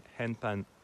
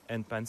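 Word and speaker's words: handpan: Le nom de mon stand est Hanpan ou Anpan ou Anpan, parfois certains le prononcent. Hanpan, c'est mieux.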